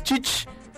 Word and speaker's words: Chich: Bueno, ya aprendimos que es Chich, es tía en Yucatán, Chich, ¿no? Así que, ¿cómo diría? ¿Cómo estás, tía Sarao? ¿Cómo sería? Chich. ¿Cómo estás, Chich? ¿No? Chich. Chich. Ahí está.